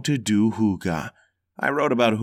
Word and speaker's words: hygge: Hygge, pronounced hygge, is a mysterious Danish word with many meanings.